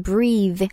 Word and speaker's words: breathe: 우선 breathe, 숨을 쉬다라는 표현이죠. s p e l l i n g 은 b-r-e-a-t-h-e, breathe, breathe. 만약에 그냥 숨이라는 명사 형태로 얘기할 때는 어, 발음이 좀 바뀌어요.